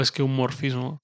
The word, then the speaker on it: esqueumorfismo: esqueuformismo. ¿Lo dije bien? – Nunca recuerdo si es esqueuformismo o esqueumorfismo. Esqueumorfismo, eso.